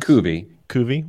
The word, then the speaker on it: cuvée: On December 2nd and 3rd, among the, is it QVs? C-U-V-E-E-S? Is that a wine or something? C-U-V-E-E-S. – Cuvée. – Well, whatever. – Cuvées.